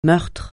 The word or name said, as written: meurtre